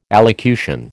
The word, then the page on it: allocution: American English